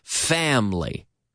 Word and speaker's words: family: I'm talking about two-syllable words that Spanish people often mispronounce as having three syllables. For example, family. Familia. Family. We don't say family. Family? No. Family. Da-da. Da-da. Family.